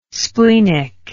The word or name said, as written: splenic